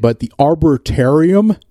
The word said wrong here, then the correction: arboretum: arbor, arbor, arboretum.